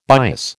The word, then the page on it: bias: American English